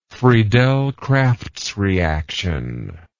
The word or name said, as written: Friedel-Crafts reaction